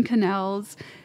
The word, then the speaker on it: quenelles: When I got there and looked around, I saw that all these chefs were making elaborate ice carvings, putting together their salmon quenelles. Quenelles? Is that how you pronounce it? I think so. Help me out. Quenelles? – Quenelle. – Great.